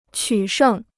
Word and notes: 取胜: qǔ shèng

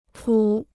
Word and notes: 谱: pǔ

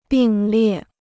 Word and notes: 并列: bìng liè

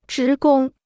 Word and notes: 职工: zhí gōng

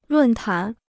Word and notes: 论坛: lùn tán

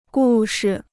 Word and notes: 故事: gù shi

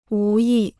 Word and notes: 无意: wú yì

